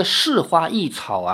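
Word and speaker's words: 莳花异草: 这个“ 是花异草” 啊，“ 是” 是草字头下面一个时间的“ 时”，读多音字，既能读“ 是”，也能读“ 时”。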